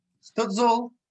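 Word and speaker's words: Stützle: Tim Schutzel. Stutzel. tout cela. Oui, ça, ça, c'est. Ça, se prononce comme Marco Sturm.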